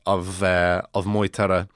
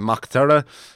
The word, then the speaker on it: Moytura: So it's Maghturiadh, as far as I know. So I've been pronouncing it Maghturra, but I've seen a few anglicized phonetic spellings of it that have been pronouncing it Moytura. So that's what I'm going to go with for the moment, until I'm contradicted or until I see one that works better.